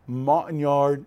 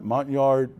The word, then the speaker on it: Montagnard: And they were of the Montagnard, I'm not saying it right, Montagnard.